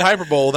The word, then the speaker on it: hyperbole: True story, Shannon used to say hyperbole. – Well, by used to, it was one time, and I got corrected, and I never said it again.